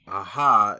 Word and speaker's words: Aja: So, Aja, Aja is usually, that would be the French pronunciation, but I don't know if he's French, or I'm sorry, Spanish.